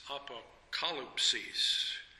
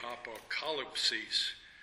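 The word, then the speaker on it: apokalypsis (ἀποκάλυψις): Apokalypsis. With the accent on the K-A, apokalypsis, which means a revelation or disclosure.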